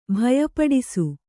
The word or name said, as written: ಭಯಪಡಿಸು